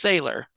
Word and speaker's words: Saylor: So it's Saylor, you said.